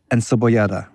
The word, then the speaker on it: Encebollada: Carne frita, and let me see if I can pronounce this correctly. Encebollada. Encebollada? – Yes. – Encebollada. – Yes, you said it right.